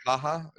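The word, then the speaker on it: Ha Ha: Is that how you pronounce his first name, by the way? Is it Ha-Ha. Early like Ha-Ha?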